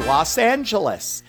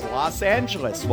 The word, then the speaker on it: Los Angeles: Los Angeles. As we all know, it's Los Angeles. Well, Jamie Lee Curtis is saying it is Los Angeles, like the street out there, Los Feliz.